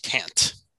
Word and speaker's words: Kant: It's pronounced Kant. – Kant. Good. Like C-A-N-T. – It's pronounced that way, but it's spelled with a K.